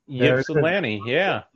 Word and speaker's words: Ypsilanti: It's in, I don't even know how to say the city, Ypsilanti, Michigan? – Ypsilanti, yeah.